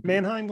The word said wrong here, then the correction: Menachem: The one that looks like Mannheim is actually pronounced Menachem.